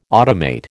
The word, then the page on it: automate: American English